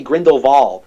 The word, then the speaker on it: Grindelwald: Actually, like how they pronounce his name, they say Grindelwald. They do the German pronunciation of the W.